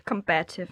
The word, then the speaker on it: combative: Og hvis der noget woke kvinder er, så er det combat, hvordan er man udtaler det? – Combative. Combative, ja.